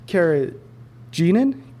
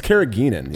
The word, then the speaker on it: carrageenan: Um, it has a high carrageenan content. – I think it's carrageenan.